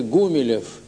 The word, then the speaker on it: Гумилев: Что касается фамилии, то отец произносил ее с ударением на первом слоге «Гумилев», потому что она происходила от латинского «humilis», что значит «смирный».